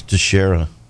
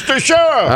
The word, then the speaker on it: Teixeira: I think that's Texera. – Okay. Yeah, you're probably right. – We don't want him calling the same. We got his name wrong, you know? It's Texera! – All right, sir. Thank you for correcting me. Texera. Although it's spelled T-E-I-X-E-I-R-A, but I guess it is. That sounds right.